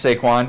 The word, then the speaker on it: Saquon: Like, first of all, it's Saquon. You're going to get his name right one of these days.